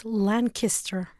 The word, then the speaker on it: Lancaster: No, it's Lancaster. – What? – It's from Pennsylvania, guys. – Lancaster? Well, that's how they pronounce it because of the Amish stuff. – Lancaster. – Yeah, and if you're over there and you say Lancaster, they look at you and they correct you.